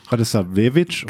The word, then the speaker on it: Radosavljević: Es gibt da noch diesen, welche Silbe man in der serbischen Sprache betont. Also. – Radosavljević? – Genau, es gibt ja zum Beispiel auch Radojević. Manche sagen Radojević oder Radojević. Oder sowas. Da gibt es, glaube ich, auch eine Regel, dass man bei vier Silben die dritte betonen muss und bei drei Silben die zweite.